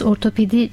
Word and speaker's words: ortopedi: Çünkü toplumda bunu birçok kere insanlar farklı telaffuz edebiliyor ama aslında kullanılması gereken kavram ortopedi kavramıdır. Ortoped dediğimiz zaman iki kelimeden meydana gelen Latince bir kelimedir aslında.